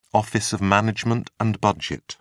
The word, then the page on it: office of management and budget: British English